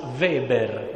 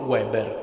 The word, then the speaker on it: Weber: L'altro è un sociologo tedesco che si pronuncia, mi raccomando, Max Weber, non Weber, perché non lo riesco proprio a sentire. In tedesco la doppia V si pronuncia V, come Volkswagen.